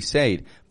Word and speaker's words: said: And notice, by the way, with the word said, I'm not saying said with S-A-I-D. It is spelled as though it should be said, but we actually say said.